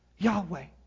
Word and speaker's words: Yahweh: And this word, this name that God is giving, can be transliterated Y-H-W-H. And most scholars recognize this to be pronounced most likely as Yahweh. Yahweh is the holy name of God.